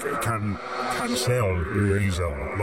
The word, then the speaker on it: Cancelerazor: Cancelerazor. Like, not getting cancelled, they call it now. You put the emphasis on the second half of the first word and it sounds a little bit like Hellraiser, a bit...